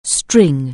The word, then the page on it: string: anglais